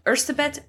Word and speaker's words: Erzsébet: Okay, so the first thing I want to say is that while she is known as Elizabeth Báthory, the Hungarian pronunciation of her name is Erzsébet Báthory.